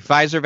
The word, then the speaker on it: Pfizer: So news of is it pronounced Pfizer? – Mm-hmm. – Okay. Pfizer vaccine's success sends Disney stock soaring.